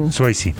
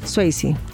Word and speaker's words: Swayze: Swayze, creo que se Swayze. – Swayze, así se pronuncia.